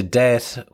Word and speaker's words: debt: Det is spelled D E B T, but the B is silent, so it's debt, not debt, debt.